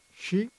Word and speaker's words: Xi: La canzone che avete appena ascoltato si intitola She, si scrive XI